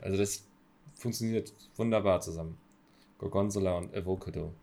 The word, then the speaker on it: Gorgonzola: Ich habe noch nie gehört, wie jemand die zweite Silbe Gorgonzola betont.